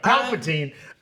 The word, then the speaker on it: Palpatine: Who's Paul Patine? – Paul Patine. Mr. Patine. No, Palpatine. I mispronounced his name.